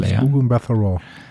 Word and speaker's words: Gugu Mbatha-Raw: Weiter haben wir auch aus der ersten Staffel bekannt Wunmi, Mosaku, Hunter B15, Eugene Cordero, Casey, Gugu Mbatha-Raw, wo ich schon bei der ersten Staffel nicht genau wusste, wie sie ausgesprochen wird. Genau so äh, wie du es gesagt hast. Gugu Mbatha-Raw.